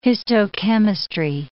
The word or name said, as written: histochemistry